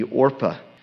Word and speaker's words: Orpah: Oprah Winfrey, when she was born, it was either her mother or the hospital that mispronounced her name. So it's Oprah. But it was really meant to be Orpah.